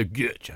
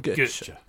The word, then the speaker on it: Götze: The number of young players that you think about when Jurgen Klopp took over, the likes of Mario Götze, so was coming through, Shinji Kagawa. – No, you said that wrong. It's got to be Mario Götze. A bit like Chaz and Dave. Götze.